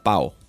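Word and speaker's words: PAHO: la PAJO. – Depende si te comes la H o la pronuncias como J. Es la PAO.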